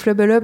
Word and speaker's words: FLBLB: Je ne sais jamais s'il faut dire FLBLB ou justement Flubble. – Comme on est en direct et qu'ils nous regardent. – Tu fais très bien, Flubble.